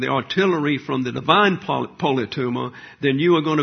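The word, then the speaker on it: politeuma: Inside the bubble, the advancing believer functions under the standards common to the heavenly polytuma. You behave as a citizen. Now let's look at the word polytuma. Polytuma is the correct pronunciation.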